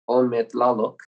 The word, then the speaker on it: Ometlaloc: It's Ometlaloc, and that's spelled O-M-E-T-L-A-L-O-C.